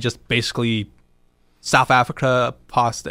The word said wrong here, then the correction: apartheid: Apartheid, yeah. – Apartheid, yeah.